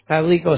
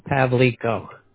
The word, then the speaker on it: Pavlico: Pavlico is spelled P as in Paul, A, V like in Victor, L-I-C-O, Pavlico. – Oh, Pavlico.